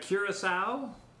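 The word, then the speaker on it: Curaçao: And so this is going to be 2 ounces of Virgin Islands rum, 1/2 ounce of orange Curacao. I think that's how you say it, isn't it? – That's how I say it.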